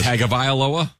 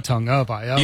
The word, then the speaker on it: Tagovailoa: And he goes, Tagovailoa? – He's like, nope. Tongue.